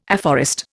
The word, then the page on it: afforest: Indian